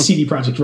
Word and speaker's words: CD Projekt: have heard me in the past pronounce it Sede. I'm just getting really tired of explaining the pronunciation. It's CD Projekt Red.